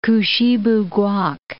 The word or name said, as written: Kouchibouguac National Park